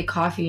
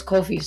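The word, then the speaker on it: Koffee: I didn't want to say coffee, you know, embarrass myself. It's Kofi. So anyways, mm-hmm. Kofi, I love you.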